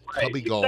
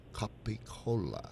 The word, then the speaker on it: capicola: It's not cubbygall. Right. It's capicola.